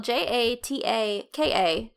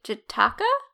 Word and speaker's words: Jataka: research, I came across a children's cartoon on YouTube called, and I feel like I'm gonna butcher the name, but it's spelled J A T A K A Jataka. Jataka tales.